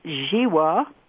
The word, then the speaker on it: Zhiwa: Now, his last name is spelled Z-H-I-W-A, but it's pronounced Ziwa, and he is a long-time eco-activist who holds a doctorate in natural law.